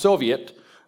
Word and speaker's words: Soviet: An economy where there's an administrating, let's say, council, which in Russian is pronounced Soviet, you have an administering council that's going to redistribute shares so that citizens are made equal, has an older name, socialism.